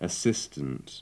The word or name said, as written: assistant